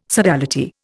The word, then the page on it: sodality: Indian